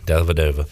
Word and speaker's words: Dellavedova: Matthew Dellavedova. – You keep putting an N in there. There's no N. – Dellavedova. – There you go. Dellavedova.